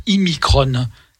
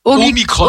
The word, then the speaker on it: Omicron: En fait, je remarquais, la télé prononce Imicron. Omicron, Omicron, Ouais.